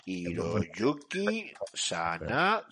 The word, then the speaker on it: Hiroyuki Sanada: es, que solo con él eh, yo es que a este hombre, vamos a ver, lo he dicho bien, ¿no? He pronunciado bien el nombre de este hombre, es Hiroyuki Sanada, ¿no? Hiroyuki Sanada, sí señor, Hiroyuki Sanada.